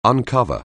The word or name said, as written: uncover